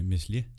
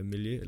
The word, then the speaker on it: Meslier: Meslier. – Meslier. Jamen jeg tror ikke, man udtaler S'et. Jeg tror, det er Melier eller sådan noget. – Melier.